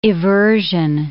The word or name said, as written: eversion